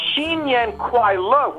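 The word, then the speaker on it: Xinyan Kuai Le (新年快乐): Yes, the Xinyan Kuai Le, which you pronounced actually very, very well, it means exactly that.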